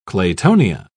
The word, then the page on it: claytonia: British English